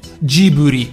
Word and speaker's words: Ghibli: Cioè, i giapponesi lo pronunciano Jiburi per l'esattezza, perché poi ovviamente anche, anche lì la trasletterazione ha i suoi, eh, ha i suoi problemi, però insomma Ghibli con la G dolce invece che Ghibli, noi italiani sappiamo bene eh, come sarebbe la pronuncia esatta del nome.